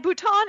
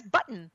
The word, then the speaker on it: Bhutan: At one meeting, Trump allegedly mispronounced Nepal as nipple and Bhutan as button, two sources told Politico.